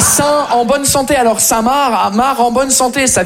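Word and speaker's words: Saint-Marc: On est d'accord, les Français, que Samar, ça s'écrit M-A-R-C, comme le prénom Marc. Mais vous, vous mettez un saint devant le prénom Marc, le C, il est parti en grève.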